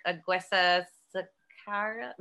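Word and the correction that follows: Aguirre-Sacasa: No, I said that wrong. Aguera Sacasa.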